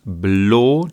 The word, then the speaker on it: Blå: Es heißt Pripps Blå. Dieser A mit einem Kreisel oben drüber wird ja auf Schwedisch O ausgesprochen.